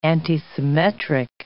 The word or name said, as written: antisymmetric